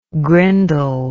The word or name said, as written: grindle